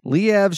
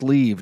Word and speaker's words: Liev: Liev Schreiber, because it's not pronounced leave, don't say it that way, is 50 years old, from Scream, Phantoms, X-Men Origins, Wolverine, and Ray Donovan.